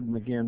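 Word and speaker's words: Magen: It looks like Magain, and I've said Magain most of my life, but I was listening to the Hebraic pronunciation today, and the guy says Magain. So, Magain, Magain.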